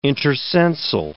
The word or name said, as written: intercensal